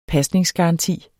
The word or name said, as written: PASNINGSGARANTI